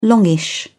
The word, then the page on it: longish: British English